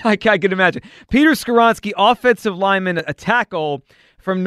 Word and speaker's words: Skoronski: So at number 10, so the Eagles' first pick, the number 10 overall pick, Peter, how do we say this? Skoronski. Peter Skoronski. – That's the one name that I got right since I've been here.